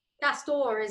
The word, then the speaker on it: Castore: Castore is what I've always said and what I've always heard.